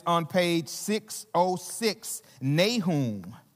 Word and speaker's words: Nahum: But today, the book of Nahum, some people pronounce it Nahum, but Nahum, I'm going to say Nahum in my southern accent, Nahum.